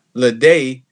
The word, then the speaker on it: Ledet: But the actual way to pronounce it is Ledet because the E-T in French is pronounced A.